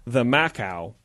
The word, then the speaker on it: camakau: called a kamaku, pronounced the Macau, even though it's spelled C-A-M-A-K-A-U, it's pronounced the Macau, without their permission.